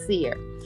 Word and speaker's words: Sear: And I spelled it and I said, well, that's not how you say it. It's not cry, it's definitely seer.